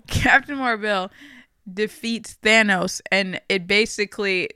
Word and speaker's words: Mar-Vell: It's annoying because they keep calling him Mar-Vell, even though it's spelled Marvel.